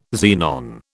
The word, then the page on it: xenon: British English